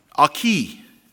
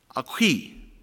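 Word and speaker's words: Aequi: He went and he led a Roman cohort, and they defeated in 15 days a group of people, and it's spelled A-Q-E-U-I, and I figured out how to say this by listening to pronunciations, and it's pronounced Aki, Aki.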